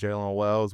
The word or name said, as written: Jaylen Wells